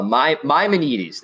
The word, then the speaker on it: Maimonides: And that's from Mia Maimonides. I think that's how you say it. – Well, Mia would be M-I-A. – Oh, Maimonides. There we go. – There we go. – Maimonides. Thank you.